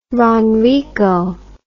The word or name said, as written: ronrico